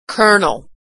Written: colonel

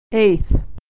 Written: eighth

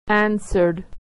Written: answered

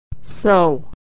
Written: sow

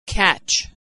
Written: catch

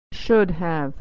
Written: should have

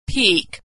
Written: peek

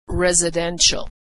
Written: residential

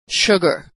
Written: sugar

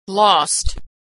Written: lost

